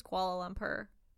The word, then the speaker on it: Kuala Lumpur: Not koala like a koala from Australia, but like Kuala. – Kuala. Okay. The flight between Kuala Lumpur and Beijing normally took less than 6 hours.